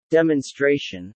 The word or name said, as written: demonstration